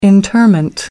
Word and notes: interment: American English

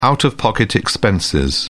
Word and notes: out of pocket: British English

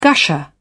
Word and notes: gusher: British English

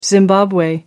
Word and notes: zimbabwe: American English

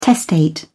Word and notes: testate: British English

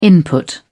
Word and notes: input: British English

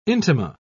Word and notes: intima: British English

